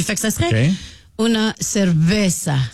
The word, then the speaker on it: cerveza: Puis là, il y a quelqu'un qui a dit au 6-12-12, faut que les Québécois arrêtent de prononcer cerveza avec un V, même si ça se lit comme ça, ça s'écrit comme ça. Mais le V en espagnol se prononce comme un B.